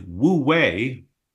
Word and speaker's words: Wu Wei: It is Wu Wei. I'll spell it capital W-U hyphen capital W-E-I.